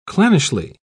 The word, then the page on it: clannishly: British English